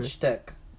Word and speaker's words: Wojtek: It's Polish pronunciation. In English, sometimes yelled Wojtek. Pronounced as such.